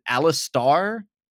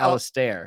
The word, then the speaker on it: Alistar: And I don't, now that I'm doubting if I know if I'm gonna pronounce it right, but is it Alistar? – Yeah, or is it Alistair? – I think it's Alistar. I think that's what it is. That's how the announcers announce it for like the League pro games.